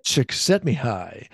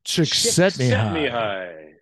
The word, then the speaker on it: Csikszentmihalyi: Is that how you say it? – Csikszentmihalyi. Say it with me. Csikszentmihalyi. – Je m'appelle Csikszentmihalyi.